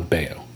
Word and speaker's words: Bayeux: I know how to pronounce the town Bayeux. – Okay, whatever. B-A-Y-E-U-X.